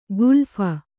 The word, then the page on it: ಖಲೀಫ: khalīpha